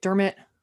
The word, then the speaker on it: Dermot: No, wait, Dermot. From My Best Friend's Wedding. – Yeah, am I saying his name right? – Yep.